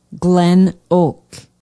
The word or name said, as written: glen oak